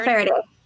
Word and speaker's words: Faraday: Scythe Fariad. Is that how I pronounce his name? Fariad? The mentor. – Oh. – He's the one he comes into Citra's home and drinks lemonade. – Is it Faraday? – Yeah, Faraday. – Yeah, Faraday. Like Daniel Faraday. Michael Faraday.